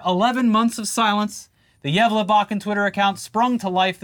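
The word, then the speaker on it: Gävlebocken: Break out the stockings, the candy canes, and eggnog, because the Yevlebakken, did I say it right? – You actually did, for the first time ever.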